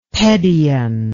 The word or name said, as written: pedion